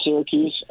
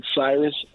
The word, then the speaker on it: Syracuse: I butchered Syracuse, and I said Cyrus, and you.